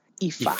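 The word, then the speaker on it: Ifá: This religion can get very machista on certain lineages, which is the Ifa. Or no, I'm sorry, I'm saying it wrong. It's Ifa. Ifa. Ifa. That's what it is. The accent's on the A.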